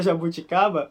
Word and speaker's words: jabuticaba: Jabuticaba. O B não é exatamente um claro B.